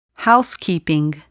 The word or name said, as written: housekeeping